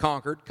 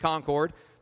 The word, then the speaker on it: Concord: I know a lot of y'all from Concord, not Concord, Concord, North Carolina.